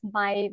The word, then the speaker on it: Mai: Ähm, aber ja, lustigerweise wird es Mai wie der deutsche Monat Mai ausgesprochen.